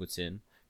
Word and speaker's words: poutine: The closest thing traditional food that Canadians have is something called a poutine, or if I'm gonna say it like an English Canadian, poutine. But that's just gay, bro. I'm just gonna call it poutine because that's how we call it because it's a French food.